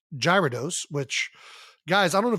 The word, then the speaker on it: Gyarados: I mean, it's got a G-Y, I think, unless we're going like Greek, Gyarados. apparently it's pronounced Gyarados, which I've never seen a G-Y go gear, but you know, whatever.